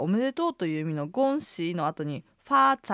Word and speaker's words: 恭喜: 恭が平板の一声、喜は下がって上がる三声です。いつもの魔の発音を使ってアクセントの流れを把握するなら、まあまあという具合です。では発音と合わせて言ってみましょう。おめでとう。 – 恭喜。